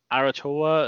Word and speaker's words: Aotearoa: Ansonsten am Wochenende geht Super Rugby Aotearoa los in Neuseeland.